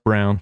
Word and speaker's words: Braun: This spelled man Braun, won. Pronounced Brown.